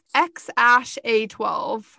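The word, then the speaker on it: X Æ A-12: So I don't know what his real name is, but they said it was X A E A-12, and apparently you pronounce that as X-A-12.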